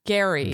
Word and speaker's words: Gerry: Okay, Jesse says something about Jerry. Oh, God. Gary. – Gary? Is it Gary or Jerry? – It's Gary, but it's spelled Jerry.